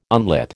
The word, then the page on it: unlit: American English